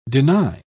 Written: deny

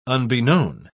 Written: unbeknown

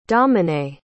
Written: dominae

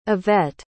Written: Ivette

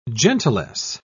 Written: gentilesse

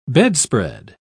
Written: bedspread

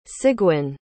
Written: sigwin

